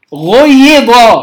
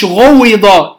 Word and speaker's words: غيض: غيض، مش غويض، لا غيض.